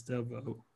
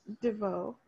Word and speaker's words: DeVoe: Oh my God, I have like trouble pronouncing Belle Biv DeVoe. I don't know why I want to say DeVoe, but it's DeVoe. Yeah. – DeVoe.